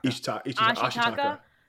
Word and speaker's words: Ashitaka: Ashitaka doesn't try to force her to come over.